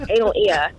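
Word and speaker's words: Analea: So it's spelled A-N-A-L-E-A. I don't know if you can spell that out, but that's anal. So that's Analia.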